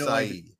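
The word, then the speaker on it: açaí: It's pronounced acai.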